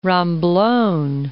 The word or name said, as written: Romblon